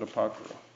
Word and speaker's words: Apocrypha: Well, actually, this one is the King James with Apocrypha.